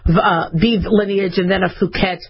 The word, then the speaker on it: Phuket: Don't pronounce it short U. Do the long U just to play it safe, Phuket.